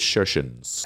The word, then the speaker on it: Kirkenes: It was a boat trip from Bergen to Kirkenes, but I'm pretty sure they pronounce K's as a sh. So we're going to go with Kirkenes.